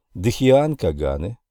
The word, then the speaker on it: Дхиан Каганы: Солнечные Питри или Агнишватта, Дхиан Каганы, здесь ударение на втором слоге,